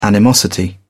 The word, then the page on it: animosity: British English